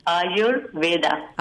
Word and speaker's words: Ayurveda: How do you pronounce, like, it's Ayurveda, but like I say, I and your and Veda. Ayurveda.